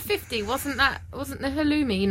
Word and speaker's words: halloumi: But it's pronounced halloumi, not halloumi.